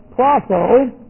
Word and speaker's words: proso: Contrast or our word with proso. I do that with a long O sound.